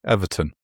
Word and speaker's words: Everton: You should pronounce the T. Everton.